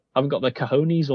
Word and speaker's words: cojones: Cojones. Okay, you know what, yeah, you're going to pronounce it right.